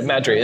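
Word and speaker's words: MadTree: And there's a local one here called, is it Mad Tree? Is that how you say it? – Yeah, Mad Tree.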